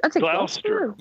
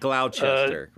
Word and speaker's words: Gloucester: Yeah, is it Gloucester? Is that how you say it? – I'd say Gloucester. – Yeah. – Gloucester. – Okay. Not Gloucester. – Yeah, I know. – Gloucester. And it's like Worcester in Worcestershire. It's not Worcestershire sauce, it's yeah.